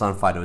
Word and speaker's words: Onfido: It's on Fido, and the way it's written in some countries, especially like European ones, they pronounce it on Fido, but on Fido is the name.